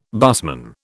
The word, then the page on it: busman: British English